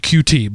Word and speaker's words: Qt: Now, another team created the Qt library, spelled QT, but pronounced Qt.